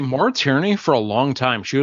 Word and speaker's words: Maura Tierney: Maura Tierney.